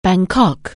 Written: Bangkok

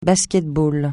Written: basket-ball

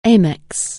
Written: amethyst

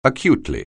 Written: acutely

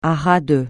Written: à ras de

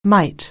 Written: mite

